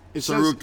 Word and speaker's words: Tsaroukas: Tsaroukas. Says, silent T, hard C.